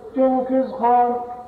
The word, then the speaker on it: جنكيز خان: فسألت عنهم رفقتي وأحبتي أصحاب جهم حزب جنكيز خاني. – اترك لي يا أخي احذفها جنكيز خاني بدون ياء. خلت تبقى بس أنت لا تنطق.